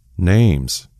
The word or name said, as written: Don